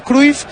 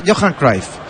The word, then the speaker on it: Cruyff: Lo pronunciaba como teóricamente lo pronunciaban los holandeses. Todos decíamos Cruyff y él era Johan Cruyff.